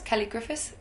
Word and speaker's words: Kelley Griffiths: Yeah, it's Kelly Griffiths, K-E-L-L-E-Y-G-R-I-F-F-I-T-H-S.